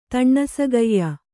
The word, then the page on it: ತಣ್ಣಸಗೆಯ್ಯ: taṇṇasageyya